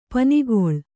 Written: ಪನಿಗೊಳ್

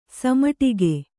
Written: ಸಮಟಿಗೆ

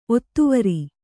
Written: ಒತ್ತುವರಿ

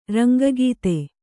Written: ರಂಗಗೀತೆ

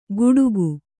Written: ಗುಡುಗು

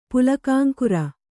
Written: ಪುಲಕಾಂಕುರ

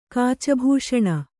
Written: ಕಾಚಭೂಷಣ

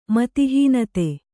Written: ಮತಿಹೀನತೆ

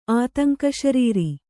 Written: ಆತಂಕಶರೀರಿ

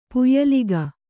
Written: ಪುಯ್ಲಿಕ್ಕು

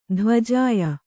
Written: ಧ್ವಜಾಯ

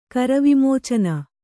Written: ಕರವಿಮೋಚನ

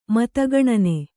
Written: ಮತಗಣನೆ

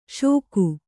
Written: ಷೋಕು

